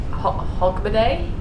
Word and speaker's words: Hogmanay: Hogmanay? Am I saying that right? – You are. That's like, that's the first day of the year?